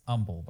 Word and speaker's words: Humble: It's Humble, by the way. Humble Texas. – No. It doesn't deserve. – That's how it's always pronounced.